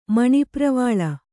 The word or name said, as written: ಮಣಿಪ್ರವಾಳ